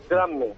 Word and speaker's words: Drammen: porque es que también estáis también estáis en Drammen, Noruega, no sé si lo pronuncio bien, hay un belén en Noruega vuestro. – Correcto, fíjate, Drammen.